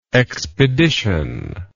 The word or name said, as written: expeditionist